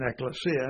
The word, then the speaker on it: ekklesia: Now, the word for assembly in Greek is ekklesia, or ekklesia. That would be the proper Greek pronunciation, ekklesia.